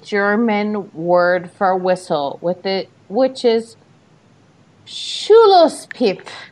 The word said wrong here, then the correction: Schlusspfiff: Schlusspfiff, that's not what Angie wrote. And you have to clearly wrote Schlusspfiff. – You have to make sure you make the right PF sound. It's like apple.